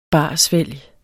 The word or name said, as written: barsvælg